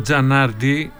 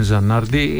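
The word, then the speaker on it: Zanardi: Ο Τζανάρντι, γιατί okay, αυτό είναι Ιταλός, ταυζί τα λογικά είναι η προφορά, απλώς τον έχουμε συνηθίσει να το λέμε Τζανάρντι,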